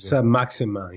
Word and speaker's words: Saint-Maximin: Is it Saint-Maximin or something like that? – Yeah, very good. Saint-Maximin.